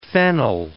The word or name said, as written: phenyl